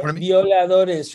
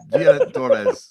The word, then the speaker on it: Violadores: So, you know, and people like yourself championing this stuff too, and like, you know, talking about Los Viadros, and probably worst pronunciation ever there, but like talking about these bands. – Yeah, Violadores from Argentina. – Yeah, Violadores, sorry.